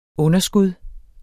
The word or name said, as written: underskud